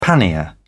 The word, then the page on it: pannier: British English